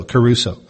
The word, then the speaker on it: kerusso: The word preaches here is translated from the Greek word keruso. It's spelled K-E-R-U-S-S-O, keruso.